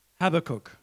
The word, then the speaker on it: Habakkuk: And I think a great example is Habakkuk. – Und ich glaube, ein wirklich gutes Beispiel ist Habakkuk. – That's how I pronounce his name.